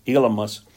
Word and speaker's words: Elymas: That's a Greek name, or that's the way it's pronounced, Elymas.